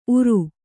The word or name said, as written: ಊರು